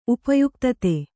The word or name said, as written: ಉಪಯುಕ್ತತೆ